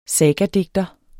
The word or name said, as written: sagadigter